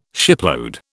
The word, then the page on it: shipload: British English